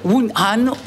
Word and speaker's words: Wuhan: Cet été, Brussels Airport vous propose de rallier une nouvelle ville en Chine, laquelle, je vais vous donner des propositions évidemment, Shanghai, Wuhan ou Chengdu. Je prononce bien, ça va Quentin ? – Prononcez extrêmement bien. – Shanghai, Wuhan ou Chengdu, on vous propose de jouer avec nous via SMS 6003, c'est 1 euro le message.